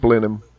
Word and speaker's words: Blenheim: Yeah, the blue dot with red dot is English, but it's pronounced Blenheim, and there's lots of English words.